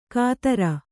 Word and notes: ಕಾತರ: kātara

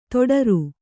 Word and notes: ತೊಡರು: toḍaru

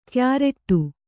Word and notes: ಕ್ಯಾರಟ್ಟು: Kannada

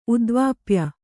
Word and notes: ಉದ್ವಾಪ್ಯ: udvāpya

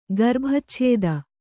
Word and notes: ಗರ್ಭಚ್ಛೇದ: garbhacchēda

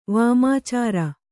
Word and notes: ವಾಮಾಚಾರ: vāmācāra

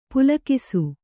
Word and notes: ಪುಲ್ಕಸ: Kannada

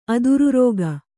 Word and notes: ಆಧಾರರೇಖೆ: ādhāra rēkhe

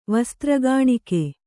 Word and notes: ವಸ್ತ್ರಗಾಣಿಕೆ: kannada

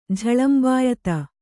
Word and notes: ಝಳಂಬಾಯ್ತು: jhaḷambāyta

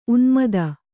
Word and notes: ಉನ್ಮೋದ: unmōda

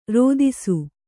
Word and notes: ರೋದಿಸು: rōdisu

